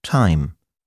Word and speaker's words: thyme: Außerdem muss man die Ausnahmen kennen. Als denglische Patienten brauchen wir uns nämlich gar nicht mit der Aussprache von TH abzumühen, wenn es ganz einfach wie ein T gesprochen wird, in Namen wie Theresa, Thomas, Thompson, Thames, Thai, Lesotho oder Thyme, dem Gewürz Thymian.